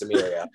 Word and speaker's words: Amelia: The first day she was like, "It's not Emily, it's Amelia." Like, no problem.